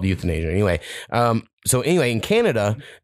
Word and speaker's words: euthanasia: Because I know people say euthanasia and you think youth in Asia, but it's not that. It like spelled like E-U-T-H, euthanasia. It's like one word.